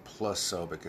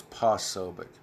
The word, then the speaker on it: Posobiec: I always pronounce his last name Posobiec. It's Posobiec. Posobiec, at Jack Posobiec.